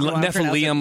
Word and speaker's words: Nephilim: It's like Nephilim, like Liam. – Nephilim.